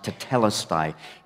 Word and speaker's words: tetelestai: In the original Greek, it's pronounced tetelestai.